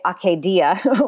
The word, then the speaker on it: acedia: So maybe you can tell us why that is as part of your explanation of sort of like, what is acedia? – Well, probably just people don't want to say akedia, which is the Greek, probably something like the Greek pronunciation.